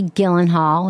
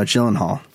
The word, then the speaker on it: Gyllenhaal: I thought it was great with Maggie Gyllenhaal. Was that how you say it? – No, Gyllenhaal.